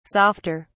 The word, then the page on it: softer: British English